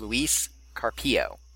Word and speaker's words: Luis Carpio: It is Luis Carpio. Second syllable is accented in both surname and first name.